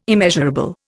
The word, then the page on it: immeasurable: Indian